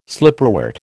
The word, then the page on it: slipperwort: American English